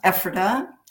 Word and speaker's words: Ephrata: It's pronounced Ephrata.